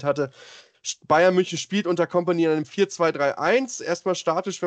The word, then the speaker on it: Kompany: Wenn man auf den Platz gucken, beim Bayern München, da muss man sagen, erstmal Trainer, auch ein alter HSV-Bekannter natürlich, Vincent Kompany, oder Vincent Kompany, wenn man es Französisch aussprechen möchte.